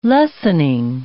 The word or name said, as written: lessening